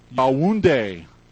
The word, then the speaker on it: Yaoundé: Pastor Chaffa is from Yaoundé.